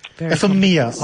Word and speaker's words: Efemia: Efemia, I say it right, like? – You do. You say it the beautiful... – Despite not being from there. – The beautiful Italian-Greek way.